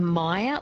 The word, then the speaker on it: Meyer: So maybe a Meyer lemon, so M-E-Y-E-R. – Yeah. That's it, yes. Excuse my bad pronunciation of it.